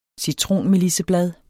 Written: citronmelisseblad

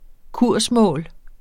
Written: kursmål

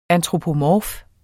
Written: ANTROPOMORF

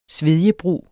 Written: svedjebrug

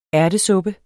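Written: ÆRTESUPPE

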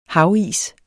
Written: havis

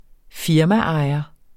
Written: firmaejer

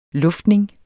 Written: luftning

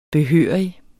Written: behørig

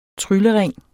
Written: tryllering

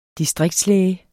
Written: distriktslæge

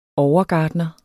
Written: overgartner

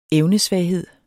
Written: evnesvaghed

